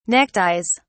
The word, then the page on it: neckties: British English